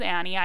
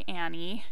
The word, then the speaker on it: Anne: So my name is Annie. I go by Annie and I spell it A-N-N-E. So most people think that's Anne. That's how most people would say it. But it's Annie. So if when I say Rethinking Fitness with Annie, it's Rethinking Fitness with Annie, A-N-N-E.